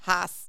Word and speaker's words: Haas: My maiden name was Haas. – H-A-A-S? – Yeah, like the avocado.